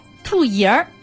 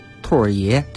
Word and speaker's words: 兔儿爷: 那么那天其实就说到了这个关于兔爷的这个发音，我听过别人说这个兔爷、兔爷儿啊，就是把儿化放在第二个音上了。正确的发音应该怎么说？ – 应该是兔爷，这个儿呢，儿化音加在中间。